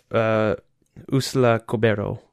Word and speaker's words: Úrsula Corberó: I have it, Úrsula Corberó.